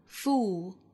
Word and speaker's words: fool: I am putting myself to the fullest possible use, 这里 F-U-L-L, full 这个词要区分和 fool 的发音，那 F-O-O-L 表示傻瓜，读成长音紧音的 oo, fool, fool, 单词尾部有舌侧音，舌尖要去找上齿音。那 F-U-U-L 读成 full, full, full, 其中包含的是松元音的 o, o 的发音。